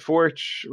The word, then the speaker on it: Forch: that Forch rhymes with torch,